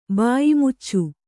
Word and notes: ಬಾಯ್ಮುಚ್ಚು: kannada

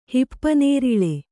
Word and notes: ಹಿಪ್ಪುನೇರಳೆ: hippu nēraḷe

